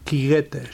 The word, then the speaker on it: quiguetes: El llenguatge popular, la pronúncia aquí és quiguetes.